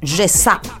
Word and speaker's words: Ceza: En realidad se pronuncia Ceza.